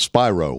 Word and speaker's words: Spiro: And it's Spiro, like Spiro Agnew, the vice president who resigned in disgrace under Richard Milhouse.